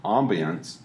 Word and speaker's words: ambiance: So if your ambiance, am I saying that right? – Yeah.